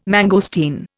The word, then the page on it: mangosteen: Indian